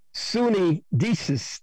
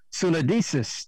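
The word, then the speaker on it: suneidesis: The word for kind, it is the word sunidesis is spelled S-U-N-E-I-D-E-S-I-S. S-U-N-E-I-D-E-S-I-S. Sunidesis.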